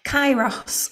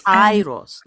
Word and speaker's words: Kairos: Is it Kairos? – Kairos. – Kairos.